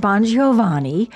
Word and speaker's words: Bongiovanni: Bongiovanni, owner of Coco Couture, owner of amazing classic car business.